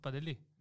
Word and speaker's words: Pantelis: When you're trying to assimilate Pantelis, it's not Pantelis; it's Padeli. – Yeah. – Yeah, the S is silent.